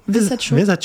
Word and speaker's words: Versace: Versace.